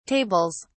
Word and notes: tables: British English

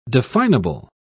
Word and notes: definable: British English

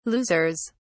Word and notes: losers: British English

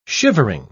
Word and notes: shivering: British English

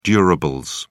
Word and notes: durables: British English

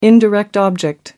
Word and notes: indirect object: American English

